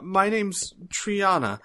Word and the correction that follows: Trinia: Or, I'm sorry, Trinia. how do you pronounce that? T-R-I-N-I-A. Trinia. My name's Trinia.